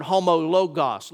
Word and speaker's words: homologos: In South Georgia, we would pronounce that Greek word homologos.